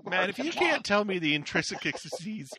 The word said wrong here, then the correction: intricacies: of how to pronounce that word. – Booby traps. – If you can't tell the intricacies of how to pronounce that word. That word is booby trapped. And it has murdered me every time. – That's intricacies? Booby trapped. – Five more times. – Intricacies. – There we go.